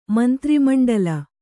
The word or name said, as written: ಮಂತ್ರಿಮಂಡಲ